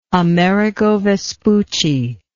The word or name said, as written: amerigo vespucci